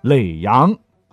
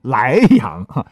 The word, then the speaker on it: 耒阳: 你像耒阳啊，经常被读成莱阳，莱阳在山东那边，好吗？